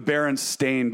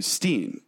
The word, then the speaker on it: Berenstain: So, for example, the Berenstain Bears, it's spelled Berenstain, A-I-N. But who knows that name? Everyone would say Steen. Steen is the common thing. So everybody collectively says Steen.